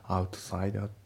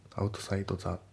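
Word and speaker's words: outside: アウトサイダーアウトサイドザじゃなくてアウトサイダーだね。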